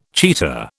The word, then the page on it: cheetah: British English